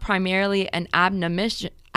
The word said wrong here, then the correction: admonition: Am I saying that right? – No. – How do you say it? – Admonition. – Admonition. Thank you.